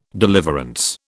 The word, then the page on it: deliverance: British English